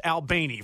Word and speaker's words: Albany: When you show up to Moultrie or you show up to Bainbridge or to Albany, which, by the way, it is pronounced Albany for those of you that don't know, in South Georgia, or Valdosta, you're going to see the Florida State Garnet Gold Spear there.